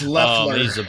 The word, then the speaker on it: Loeffler: I mean, I don't know how to say his last name either. So it's Loeffler.